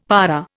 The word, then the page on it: para: Indian